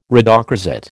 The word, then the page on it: rhodochrosite: American English